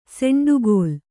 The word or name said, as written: ಸೆಂಡುಕೋಲ್